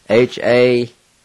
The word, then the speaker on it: hadida: And it's spelled H-A-D-I-D-A. H-A-D-I-D-A. Number two is, they are fighting. And it's spelled A-N-A-T-L-I. A-N-A-T-L-I.